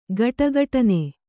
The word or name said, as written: ಖಡಖಡನೆ